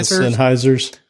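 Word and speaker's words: Sennheiser: Oh, yeah, Sennheisers.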